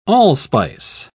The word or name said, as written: allspice